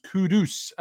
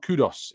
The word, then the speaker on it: Kudus: LJ says, please, guys, his name is pronounced Kudus, not Kudos.